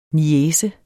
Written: niece

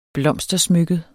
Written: blomstersmykket

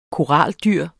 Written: koraldyr

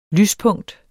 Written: lyspunkt

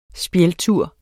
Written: spjældtur

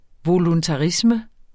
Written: voluntarisme

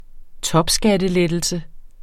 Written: topskattelettelse

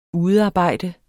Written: udearbejde

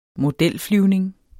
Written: modelflyvning

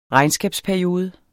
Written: regnskabsperiode

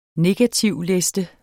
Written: negativliste

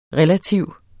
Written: relativ

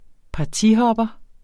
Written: partihopper

